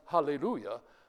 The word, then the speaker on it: hallelujah: So it is actually pronounced hallelujah, but most Greek texts don't use the full punctuation and leave out the rough breathing mark, and so you get alleluia.